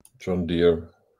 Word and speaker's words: John Deere: John Deere.